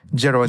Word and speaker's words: Jeronimo: Izakaya Jeronimo. Mm-hmm. – Yes. – I, I, think they yes. pronounce it as Jeronimo, no? – Izakaya yeah. – Geronimo. – Geronimo. Mm.